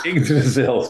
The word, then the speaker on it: Yggdrasil: Yggdrasil, thank you.